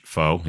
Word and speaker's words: faux: How do you say that word again? – Faux. Which? Faux, yes.